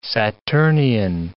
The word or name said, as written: Saturnian